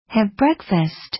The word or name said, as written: have breakfast